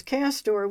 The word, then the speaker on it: castor: The French name for beaver is Castor, with the accent on the last syllable, Castor.